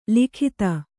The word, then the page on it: ಲಗತು: Kannada